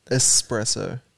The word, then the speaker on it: espresso: How are you supposed to say it? – Espresso. – S. – It's not an X.